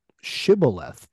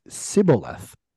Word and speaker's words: shibboleth: could not pronounce shibboleth because it would come out sounding as sibboleth. And so if you ask them to say the word and they pronounced it wrong, they were the enemy.